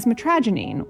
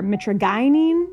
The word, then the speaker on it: mitragynine: The active compound in kratom is known as metragenine, or mitragynine, depending on who you're talking to. Either pronunciation is fine.